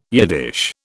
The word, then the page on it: Yiddish: British English